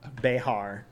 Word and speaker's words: Bexar: B-E-X-A-R? – No, that's not in Bear County. No. Behar. The correct way to say it.